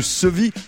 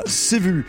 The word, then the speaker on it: ceuvi: Oui, mais à dire vrai, Yasmina, on trouve développé le sevi orthographié C-E-U-V-I pour qu'on ne prononce pas sévu